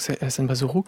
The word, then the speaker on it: Senbazuru: Alors en japonais, ça se prononce donc Senbazuru.